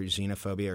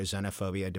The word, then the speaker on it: xenophobia: And this last year, we updated the note for either xenophobia or xenophobia, depending on how you want to say it. Although xenophobia is the older traditional pronunciation, xenophobia has also been around for a very long time.